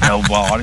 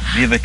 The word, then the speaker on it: Belvoir: Didn't bring us anything, Mr. Belvoir, if you're listening. Or is it pronounced Beaver Castle? – Either way.